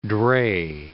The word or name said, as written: dray